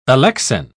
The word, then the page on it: alexin: British English